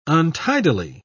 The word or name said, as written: untidily